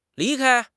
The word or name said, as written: li2 kai1